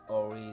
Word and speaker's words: Aury: Again, it's Ori Sabushi Mike, your host. Your only host this time. Ori Sabushi Mike. Ori is my first name. And it's spelled A-U-R-Y Sabushi Mike. It's S-A-B-U-S-H-I-M-I-K-E.